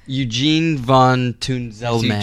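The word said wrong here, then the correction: Eugénie: But it's got a little accent on the E, so it's Eugenie.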